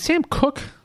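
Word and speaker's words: Koch: Longtime Ravens punter, is it Sam Cook? Is that how you pronounce it? – It is.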